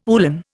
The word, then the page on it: uhlan: Indian